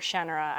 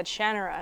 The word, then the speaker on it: Shannara: remember getting all of the Shannara books, I remember, or Shannara actually is apparently how you say it, really? Oh, which I didn't know. – No, I didn't know this. I heard Terry Brooks speak at an event and he said Shannara, and I was like, what?